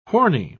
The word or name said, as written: porny